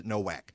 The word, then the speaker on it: Nowak: His last name is spelled the same as mine, but he pronounces it Nowak.